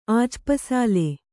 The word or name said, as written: ಆಜ್ಪಸಾಲೆ